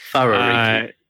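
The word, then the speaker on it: Wharariki: Wawariki. That's one of the things that confuses people. – Wait, how do you WHA is pronounced with an F.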